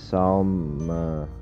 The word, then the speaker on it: Salmagundi: So the word of the day is Salmagundi. Salmagundi. Could be Samagundi, like Sam, like salmon, because it's spelled like Solomon, but obviously Solomon, not the same thing.